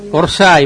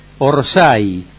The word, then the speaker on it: Orsay: No, bueno, sí. Orsay, vamos a ponerlo en términos futbolísticos. Orsay.